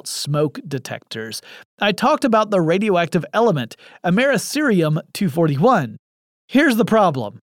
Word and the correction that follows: americium: I inserted an extra syllable there, and even in my notes I had it spelled correctly. It's americium 241.